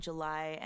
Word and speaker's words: JLY: Yes, I am JLY, and that's spelled J-L-Y because it's actually my initials.